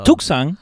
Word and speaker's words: Tucson: Se escribe Tucson, you know, pero Tucson, como le dicen.